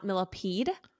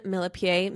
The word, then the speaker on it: Millepied: Apparently it is not Millipede. It's Millepied. Okay. So we broke down the recent cheating scandal on our most recent episode, everyone, I incorrectly pronounced Benjamin's last name as Millepied because that's how it's spelled. – Right. – But apparently it's French and it's actually pronounced Millepied.